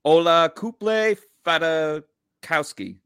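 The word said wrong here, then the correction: Olakunle Fatukasi: No, that's not it. Fadukasi. – Olakune Fadukasi. – Olakune Fadukasi. Yeah.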